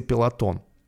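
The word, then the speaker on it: Peloton: Вчера, кстати, Пилотон. А мы э, думаю, и эту тему затронем. Прямо сейчас ее можем затронуть. Вчера рухнули акции Пелотон. Пелотон, да, пишется.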